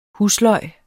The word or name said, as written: husløg